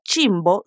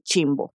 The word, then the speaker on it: chimbo: Otro detalle particular de la pronunciación de chimbo es la CH al inicio. Esta combinación de letras no suena tan suave como en portugués, sino que en español pronunciamos la C seguida de la H de una forma más fuerte.